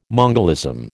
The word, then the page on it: mongolism: American English